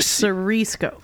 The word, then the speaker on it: Siriesco: Siriesco.